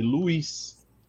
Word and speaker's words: Luiz: Meu nome lá no Japão, eles não conseguem pronunciar o meu nome, Luiz. Porque a letra L lá não existe. Então lá é Ruiz. Tá vendo? Ruiz.